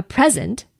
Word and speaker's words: present: If I accentuate the second syllable, I get present. So present versus present. Changing that syllable accentuation by accentuation changes it from a noun to a verb.